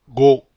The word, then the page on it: go: Spanish